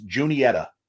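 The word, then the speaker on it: Juniata: The first towns that are spelled differently and pronounced differently, J-U-N-I-T-A. I think that's how it's Junietta. – Junietta. – Okay. The second one is Juanita. W-U-A-N-E-T-A. Okay. And anytime people see the first one, they want to say Juanita because it's spelled like Juan, J-U-A-N. So Junietta and Juanita. If you say them wrong, people know you're an outsider.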